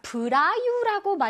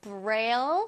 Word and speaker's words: Braille: 자, 브라유라고 많이 읽는데요. 한국어로는 읽을 때 브라유라고 읽는다고 합니다. 근데 브라유라고 하면 뭔가 보라유 이러는 것 같아서 자, 우리는 브레일라고 멋지게 발음을 해주세요. 오케이? 브레일, 브레일. 오케이.